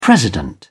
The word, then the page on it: president: British English